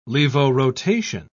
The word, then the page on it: levorotation: British English